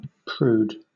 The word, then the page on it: prude: British English